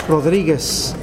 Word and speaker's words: Rodriguez: Rodriguez, R-O-D-R-I-G-U-E-Z.